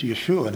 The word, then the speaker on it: Yeshua: It's Jesus, not Yeshua. I mean, it's Yeshua, not Jesus. We've kind of corrupted that one with a mispronunciation of the German J.